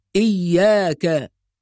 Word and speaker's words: إياك: يبقى إذن على القارئ أن يحافظ على توضيح الشدة، إياك، هكذا ننطق، إياك نعبد وإياك نستعين.